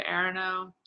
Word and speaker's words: Aronow: Um, Rebecca Aronow is how you pronounce my last name.